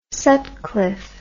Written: Sutcliffe

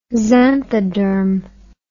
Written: xanthoderm

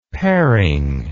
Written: paring

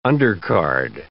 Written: undercard

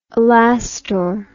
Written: Alastor

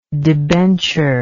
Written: debentured

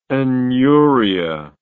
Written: aneuric

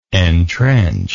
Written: entrenched

